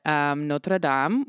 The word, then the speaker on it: Notre Dame: Also, this is a little bit to do with, I don't know if anybody noticed in the news this week, but Notre Dame, which is the big cathedral in Paris, You said it correctly, too.